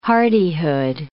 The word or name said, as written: hardihood